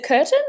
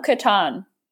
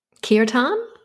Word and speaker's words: kirtan: But with you as well, you do some little mantras on the harmonium, and is it, excuse my pronunciation, is it curtain or kirtan? – Kirtan. – Kirtan, thank you.